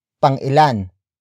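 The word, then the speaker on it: pang-ilan: Word for word it's, pangilan, si, Wilson, sa, karera.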